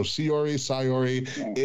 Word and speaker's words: Syuri: And at number 1, from Stardom, I believe, I'm going to try to see if I can say her name correctly, Sayori, S-Y-U-R-I, so Sayori, is the number 1 rated women's wrestler.